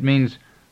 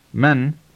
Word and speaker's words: من: However, you may hear it pronounced with slight variations in different regions. For example, it is pronounced men in the central part of the country, while it is mean in the west. Throughout this course, you will be hearing and saying one form only, namely men.